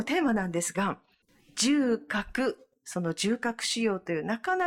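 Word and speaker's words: 縦隔: はい、縦に隔てると書いて重核と読みますが、重核という臓器があるわけではなくて、左右の肺の間にある空間のことを重核と呼んでおります。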